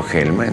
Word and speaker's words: Gelman: Cuando llega a la Argentina, como es el papel venía en caracteres cirílicos, evidentemente le preguntaron cómo se pronunciaba y él dijo Gelman, entonces anotaron Gelman. Tal cual suena.